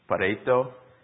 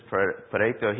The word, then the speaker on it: Pareto: There's a theory or a rule that was penned in the late 1800s by an Italian economist named, I think it's pronounced Pareto.